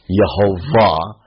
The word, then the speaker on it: Yehovah: If you tried to read it the way, or speak it the way that it's written, you would pronounce it Yehovah. And the W sound of ancient Hebrew in modern Hebrew is a V sound, so you get Yehovah.